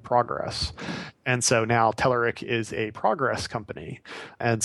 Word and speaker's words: Telerik: And so there's two pronunciations depending on, I guess, where you throw the accent. I say Telerik. The Bulgarians tend to say Tel Eric. And I like Tel Eric.